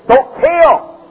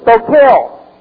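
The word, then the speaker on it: stoicheo: And it's the word stokeo. – 那这样他这边写的这个 walk 是 stokeo. – Let's repeat it with me. Stokeo. – Stokeo.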